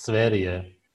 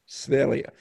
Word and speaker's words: Sverige: It means Sweden in Swedish. It's pronounced Sverige. – Sverige.